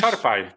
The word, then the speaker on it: czarpaj: Powiedz mi, jak to się wymawia? Ciarpaj czy jakoś... – Czarpaj, czarpaj. – Okay.